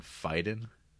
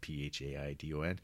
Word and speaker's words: Phaidon: So there's the two art book, kind of the ones you find in most bookstores, there's Taschen and Phaidon, Phaidon. I think that's how it's pronounced.